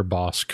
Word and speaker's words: Bossk: It's just about the bounty hunter Bossk from the Star Wars. – Yep, and we're real particular about you pronouncing all of the hard K sounds.